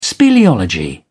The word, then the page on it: speleology: British English